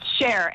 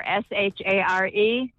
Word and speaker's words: Shar: S-H-A-R. – No, you were wrong. – S-H-A-R. It's Cher, like Cher the singer, correct? – It's like Share.